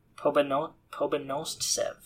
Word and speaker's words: Pobedonostsev: I'm going to say his name right this time. Pobedonostsev. I had to stutter there, but I got it. Pobedonostsev.